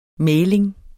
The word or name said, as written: mægling